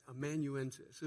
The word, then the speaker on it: amanuensis: How do you say that right? Amanuensis.